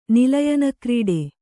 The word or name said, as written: ನಿಲಯನಕ್ರೀಡೆ